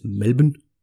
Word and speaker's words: Melbourne: So she was born in Melbourne, Australia in 1945, or I think the correct pronunciation is Melbourne, something like that.